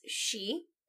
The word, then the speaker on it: sidhe: But we are used to seeing the Gaelic spelling for fairy as S-I-D-H-E, and it being pronounced she.